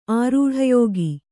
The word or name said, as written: ಆರೂಢಯೋಗಿ